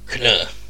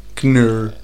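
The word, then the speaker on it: Khn'nr: The fifth Captain Marvel was a Skrull sleeper agent named Knur. – Knur. – I'm going to spell that. – Okay. – K-H-N apostrophe N-R.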